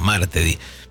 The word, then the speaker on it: martedì: Y martedì es martes. O será martedì. O martedì. No, martedì. Pero como no tiene acento en la...